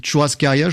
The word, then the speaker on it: churrascaria: une churrascaria, je le prononce bien. – Très bien, churrasco.